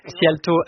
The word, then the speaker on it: Szijjártó: Szijjártó